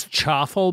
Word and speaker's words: chaffle: If look, if you Should have an actual recipe, it's chaffle because it's spelled like waffle.